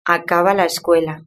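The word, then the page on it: acaba la escuela: Spain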